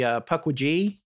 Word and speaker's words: Pukwudgie: I've changed my pronunciation of that after I heard a Native American person say, they emphasize the middle syllable on that. I think really? – Pukwudgie, okay.